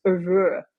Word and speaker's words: oeuvre: So I was trying to get different types and different representations of different types of songs, and then from different parts of his oeuvre. Is that how you say it? Yeah.